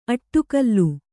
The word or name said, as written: ಅಡಿಗಲ್